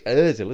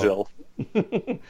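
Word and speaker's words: Özil: It's Ozil, isn't it? As if it's you are. Ozil.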